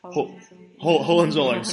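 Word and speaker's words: Hohenzollern: the Hohenzollerns, or however you pronounce it. – Hohenzollerns, thank you.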